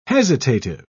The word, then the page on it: hesitative: British English